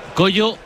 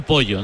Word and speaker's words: Kojo: Es Coyo, no Pollo, ¿no? – Coyo se dice, pero se escribe Cojo, K-O-J-O.